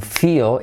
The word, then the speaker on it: feel: I don't feel, it's a feel, es casi como dos sílabas, feel, la L es tan potente que casi obliga a hacer una sílaba más,